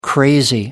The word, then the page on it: crazy: American English